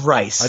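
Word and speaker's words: Reiss: However, ESPN Boston's Mike Rice, Reese, is it Rice or Reese? I never know how to pronounce that. I think it's Rice. – I think it's Rice also.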